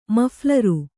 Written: ಮಫ್ಲರು

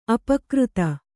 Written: ಅಪಕೃತ

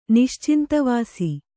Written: ನಿಶ್ಚಿಂತವಾಸಿ

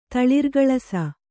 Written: ತಳಿರ್ಗಳಸ

